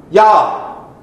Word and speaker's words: Yah: And we learn the way to pronounce God's personal name, if you please, when we look at the Hebrew word hallelujah, last part, Yah.